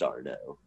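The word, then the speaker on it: Arnault: It's spelled funny, but I think it's pronounced Arno.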